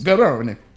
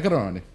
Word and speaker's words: Garrone: Yes, Garrone. He's Italian. Matteo Garrone. That's how you say it.